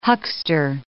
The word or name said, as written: huckster